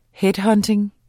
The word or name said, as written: headhunting